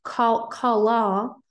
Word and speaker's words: kalah: The Hebrew word that is used here is pronounced kalah, which means to lightly esteem, to think of Israel like as insignificant or trivial.